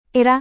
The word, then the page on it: era: indonesian